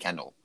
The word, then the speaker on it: Kendall: It's definitely Kendall. – Kendall, so that's frustrating. Right? – Yeah. – How do you pronounce it? – You know, you're 100% right. I've been saying like Kindle, one of those reading tablets, like shortening the Kendall to Kindle.